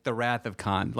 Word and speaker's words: Cannes: We we went to the Cannes Film Festival. That's how you say it, by the way. It's not Cannes.